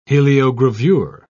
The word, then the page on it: heliogravure: British English